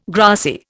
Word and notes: grassy: Indian

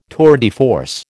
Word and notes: tour de force: American English

